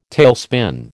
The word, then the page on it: tailspin: American English